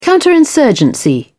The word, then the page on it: counterinsurgency: British English